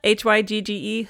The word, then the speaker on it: hygge: There's this Danish spirit of hygge, H Y G G E, hygge, I looked it up how to pronounce it.